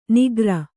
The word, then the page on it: ನಿಕರ: nikara